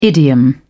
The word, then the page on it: idiom: British English